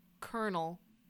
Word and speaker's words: colonel: What, why does Colonel, which is spelled Colonel, have an R sound? And it said it's because Colonel came to English from the mid-16th century French word coronel, meaning commander of a regiment or column of soldiers. – By the mid-17th century, the spelling and French pronunciation had changed to colonel.